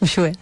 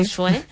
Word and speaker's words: Uxue: Alberdi, ¿lo pronuncié bien? – Uxue, sí. – Uxue.